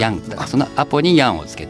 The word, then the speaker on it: アポ: ァベットに略して 3 レター 3 文字で略して何かを事物を表すことがあるんだけどその中の一つで空港のことをアポエアポートを略して APO で「アポって業界では空港のことをアポっ